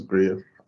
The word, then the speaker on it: Griot: Can you introduce yourself, who Riot Studios is, for our listeners? – So it's Griot Studios, or Griot. – Griot. – Okay. Griot. Griot or Griot. The original pronunciation is Griot.